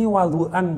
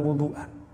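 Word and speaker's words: wadu'an (وضوءا): Ini wadu'an bacanya bukan wudu'an.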